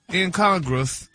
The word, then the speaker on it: incongruous: You're still saying it wrong. – Well, look, God. – What's going on? – Okay. – What? – What did you say at first? – I said incongruence because that's what they had. – Is that not it? I thought that was it. – No, they said it's not it. They said, honey, the writer wrote it wrong. – No, he had the last syllable wrong. Now you got the right syllables, and it's broken down for you.